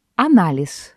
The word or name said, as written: анализ